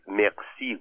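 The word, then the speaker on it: merci: مقسی گفت همون کلمه مرسی فرانسه با تلفظ فرانسه.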